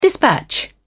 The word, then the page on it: dispatch: British English